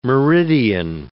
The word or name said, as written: meridian